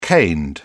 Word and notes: caned: British English

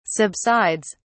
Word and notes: subsides: British English

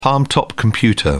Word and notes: palmtop: British English

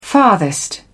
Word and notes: farthest: British English

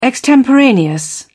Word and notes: extemporaneous: British English

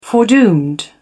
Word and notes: foredoomed: British English

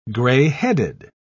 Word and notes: grey-headed: British English

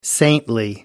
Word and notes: saintly: British English

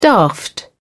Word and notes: daft: British English

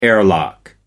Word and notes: airlock: American English

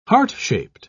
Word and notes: heart-shaped: British English

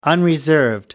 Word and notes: unreserved: American English

